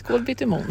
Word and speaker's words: Kolbittemon: I orten Kolbittemon. Visst är det så? Uttalar det rätt? – Ja, helt rätt. Kolbittemon.